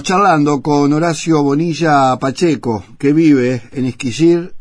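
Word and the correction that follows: Eskişehir: Decímelo de vuelta, Horacio. Esquisehir. Esquisehir. Esquisehir. ¿eh? Lo dije bien ahora, ¿no?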